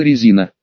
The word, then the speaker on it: Резина: Понятия не имею, что в нашей сфере забыла Резина. Фамилия у нее такая, с ударением на первый слог. Мы, само собой, ставили его на второй, а в чате обменивались колкостями и ассоциациями.